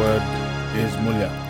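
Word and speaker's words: mulha: Mulya.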